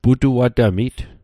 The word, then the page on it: putuatamit: Mi'gmaq